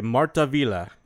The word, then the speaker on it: Martinville: It was Edouard Léon Scott de Martaville. Yeah, the last name's spelled Martinville. I'm just going to tell you that right now. So, what the guy pronounced it, left out the N.